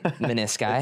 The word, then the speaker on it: menisci: How do you say the plural of meniscus? – I figured you knew. – Menisci? – That sounds right. Menisci.